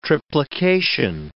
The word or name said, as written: triplication